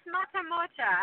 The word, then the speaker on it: Matemotja: But, Angela, so I watched another interview with you, and somebody's like, how do you say your last name? And I'm like, I think I got it, but it's Matemotja. Is that right? Did I get your name right? – Yeah, that's pretty close. Matemotja, absolutely.